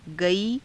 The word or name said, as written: gaii